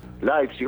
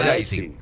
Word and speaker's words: Leipzig: Leipzig, ¿cómo se dice? Bocha, Leipzig. Con A.